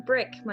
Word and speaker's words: Bryk: You can find me on TTRPG as Jacqueline Bryk. My last name is spelled B-R-Y-K, not B-Y-R-K, not B-R-I-C-K, not B-R-I-K.